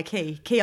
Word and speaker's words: Quay: They're Key ones. That's how you say it, right? Q-U-A-Y, Key Australia.